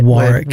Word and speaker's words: Warwick: And always gives me like, yes, you said her name right. – You did, because you would normally say that Warwick. – Warwick, yeah. And it is Warwick, so well done.